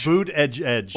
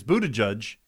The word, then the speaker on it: Buttigieg: Boot edge edge. – Why does he say that? Well, is that what how he, it looks? – I think he's making fun of the spelling and pronunciation. It's Buttigieg.